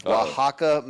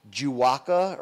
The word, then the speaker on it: Oaxaca: Yet you're going to say Juaca or Ojaca? – I didn't say that.